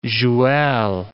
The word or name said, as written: joual